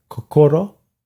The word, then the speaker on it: kokoro: I called some Japanese cultural organizations, and they said kokoro.